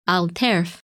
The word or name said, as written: Alterf